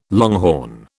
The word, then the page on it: longhorn: British English